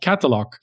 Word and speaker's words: Qatalog: He is the founder of a company called Qatalog, Qatalog with a Q.